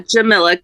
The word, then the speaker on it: Jamila: Yeah, Jamila, kind of like Camilla, but with a J in front of it instead.